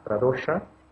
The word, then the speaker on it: pradosha: Si uno pone pradosha, que se deletrea P-R-A-D-S-H-A, pradosha, se pronuncia pradosha.